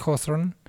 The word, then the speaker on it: Hawthorne: que es homónima al mejor libro de Nathaniel Hawthorne.